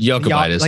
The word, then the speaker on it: Jakubaitis: I think it's Jakubaitis. – Jakubaitis? – I think the J might be silent. – Jakubaitis. – Like yogging. Yeah.